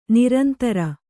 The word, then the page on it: ನಿರಂತರ: Kannada